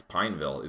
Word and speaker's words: Pineville: It's Pineville. It's two syllables, and it also has a plant.